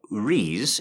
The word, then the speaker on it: Reisz: It's from Tiffany Rees, is how I'm going to pronounce it. R-E-I-S-Z.